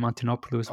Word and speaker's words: Antonopoulos: And I think with ah yeah with Andreas Antolop I can't say his name Antonopoulos